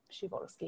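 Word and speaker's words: Przeworski: Adam Szyborski, also known as Szyborski. He's Polish, there's a P there, and it is pronounced in Polish, Szyborski.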